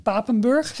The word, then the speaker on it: Papenburg: Voor onze donateurs, als exclusief uitje, heb je de mogelijkheid om met ons mee te gaan naar de Meijerwerft in Papenburg.